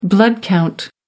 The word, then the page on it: blood count: American English